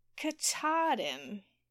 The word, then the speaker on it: Katahdin: Katahdin, Maine.